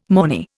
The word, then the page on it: mornay: Indian